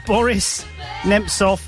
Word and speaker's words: Boris Nemtsov: 1 year ago, Boris Nemtsov was assassinated. Is that how you say it? – Yeah.